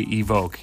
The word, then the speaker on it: evoke: I love that some people still can't say evoke. It's an actual English word. E-V-O-K-E. That's how you say it.